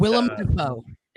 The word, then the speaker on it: Willem: But Willem Dafoe.